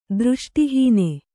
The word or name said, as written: ದೃಷ್ಟಿಹೀನೆ